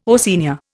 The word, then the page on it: Oceania: Indian